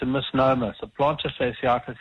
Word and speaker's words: plantar fasciitis: Yeah, plantar fasciitis. That's F-A-S-I-I-T-I-S.